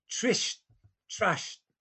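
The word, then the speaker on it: Tritsch-Tratsch: Well, Jay, you nicely swerved my request there to pronounce the music, which I have trouble with, which is Trish Trash Polka. – That's correct.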